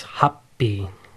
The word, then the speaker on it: happi: Let me pronounce that right. It's happi. So it's ha and a small tsu and then p. So happi. In English or in Romaji, it would be H-A-P-P-I, and you put a little pause there.